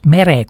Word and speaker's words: Mere: Nii et see Meri kuvand , vabandust , Mere kuvand , Meri , Mere hääldub , nimi hääldub nii nagu nimisõna eesti keeles .